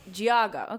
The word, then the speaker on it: Geauga: Is that, did I say that right? Georgia County. – Geauga.